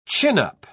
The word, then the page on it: chin-up: British English